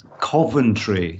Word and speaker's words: Coventry: And not a bad pronunciation, but it's Coventry. So Coventry. – Coventry, you know, so rather than Coventry, which it's not quite. Yeah, you got to hit the vowel a bit harder.